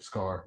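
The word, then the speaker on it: Skaar: I don't know how to pronounce Scar. Is that how you pronounce it? – Scar. – Yep. Scar.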